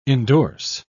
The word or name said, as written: endorse